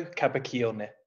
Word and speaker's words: Capaccioni: Oh, not far off, not far off. So it's a Stefano Capaccioni. – Oh my gosh, there's a hard C in there.